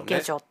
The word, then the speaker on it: 下女: そうですね、うんうん 60 年に撮られたモノクロの映画なんですけど、うんうん、下の女って書いて下女って読むんですが、ねまあ、何のことって言うとお手伝いさんのことを多分下女って言うのかな。